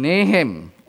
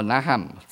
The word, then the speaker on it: Nahum: And Nahum, or Nahum, depends how you pronounce it, to Nineveh.